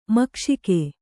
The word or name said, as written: ಮೋಕ್ಷಕ